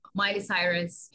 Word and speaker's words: Miley Cyrus: Mayo Cyrus? – Yeah, Miley Cyrus.